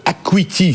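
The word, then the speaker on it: Hackuity: Ça s'écrit H A C K U I T Y. C'est ça. C'est une signification hack. C'est le hacker. – Acuteur, hacker, euh, acuité, acuité visuelle.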